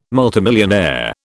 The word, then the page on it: multimillionaire: British English